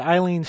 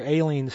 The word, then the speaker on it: Aileen: Before that, Eileen Stanley, or Aileen Stanley, as it is more correctly pronounced in Johnny Marvin, 1927, Under the Moon.